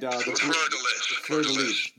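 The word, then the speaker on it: fleur-de-lis: She made him a bracelet of the colors of the Fleur de Lure, if I said that right. And Fleur de Lis.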